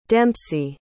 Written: dempsey

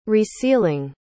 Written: resealing

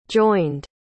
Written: joined

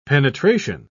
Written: penetration

